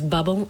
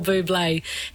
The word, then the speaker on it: Bublé: I spent the first half of my life pronouncing this as bubble. I'm very sorry, Michael. It's Michael Bublé.